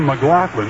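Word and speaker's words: McGlothen: This pairing tonight with a left-hander Rooker and a right-hander Lynn McLaughlin. That's M-C-G-L-O-T-H-E-N, and it's pronounced McLaughlin, Lynn McLaughlin.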